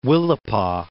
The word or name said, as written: Willapa Bay